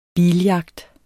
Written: BILJAGT

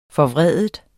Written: forvride

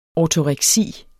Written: ortoreksi

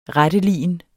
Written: retteligen

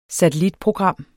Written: satellitprogram

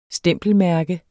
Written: stempelmærke

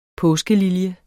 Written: påskelilje

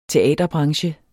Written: teaterbranche

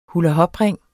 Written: hulahopring

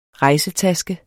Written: rejsetaske

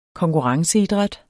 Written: konkurrenceidræt

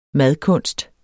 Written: madkunst

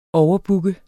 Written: overbooke

